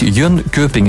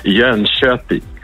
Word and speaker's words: Jönköping: Ja, het is eigenlijk Jönköping. Jönköping. Dus de K voor de omlaut wordt heel zacht uitgesproken in Zweden.